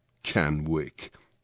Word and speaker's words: Canwick: That's the cool way to pronounce it, by the way. Canwick. Two separate words.